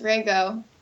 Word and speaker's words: Rago: Almost. Rago. It's like I used to spell it like R-A-Y-G-O, just so that people wouldn't mess it up.